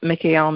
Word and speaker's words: Mikael: It's not necessarily Hebrew pronunciation of his name, but the pronunciation that I use in the chant is Mikael, Mikael, Mikael, um, and then the Hebrew uh, names for God. So Adonai Elohim, Adonai Eloheinu, and then Mikael, Mikael, Mikael again.